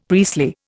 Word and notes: priestly: Indian

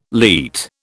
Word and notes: leat: British English

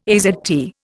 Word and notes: AZT: Indian

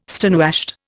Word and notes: stonewashed: Indian

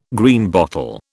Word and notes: Greenbottle: British English

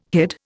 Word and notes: hid: Indian

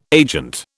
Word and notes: agent: British English

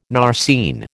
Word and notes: narceine: American English

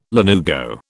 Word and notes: lanugo: British English

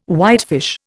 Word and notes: whitefish: Indian

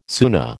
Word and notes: Sunna: American English

